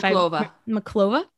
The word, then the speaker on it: Monclova: I'm sorry if Monclova. – I'm sorry. I mispronounced that in the Mexican League after only 33 games.